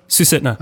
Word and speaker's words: Susitna: So is it Susitna? Is that how you say the river? – Susitna. – Okay. It's like Susitania. Where's that river? – Andrea named our cat Susitna.